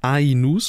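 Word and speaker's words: Aïnouz: Kristen Stewart, Josh O'Connor und Al Fanning in Karim Anius I e. Ainus, Entschuldigung, äh, brasilianischer Regisseur, ähm, kannte ich vorher nicht, in Karim Ainus rosebush Pruning.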